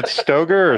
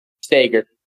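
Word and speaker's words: Stoeger: Wait, is it Stoger or Stager? – Stager. – Stager. I've been saying Stoger for the longest time. – It makes no sense, because it's spelled like Stoker. – It's spelled like the shotgun, but no, it's Stager.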